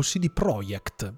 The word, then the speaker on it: CD Projekt: è già stato dichiarato da CD Projekt o, o, CD Projekt, perché la pronuncia è esatta, è proprio quella lì, e um, arriverà basato sulla saga di...